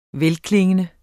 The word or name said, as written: velklingende